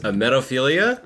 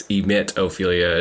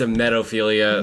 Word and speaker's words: emetophilia: That's how it's pronounced. Mm-hmm. Which the entire time this week I would think it's emetophilia, as in you emit vomit. – I thought that too, yeah. – But it's it's emetophilia, mm-hmm.